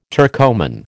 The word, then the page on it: Turcoman: American English